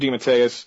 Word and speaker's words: DeMatteis: DeMatteis, sorry, I've been pronouncing it wrong, J.M. DeMatteis 100% unequivocally knows Kane better than I do, so so I'll accept it.